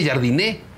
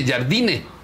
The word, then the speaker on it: Jardine: no se dice jardiné, dice jardine.